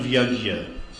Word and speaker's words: yajna: As I quoted the other verse, work has to be performed for the satisfaction of yajna. That's again the Hindustani pronunciation. Yajna.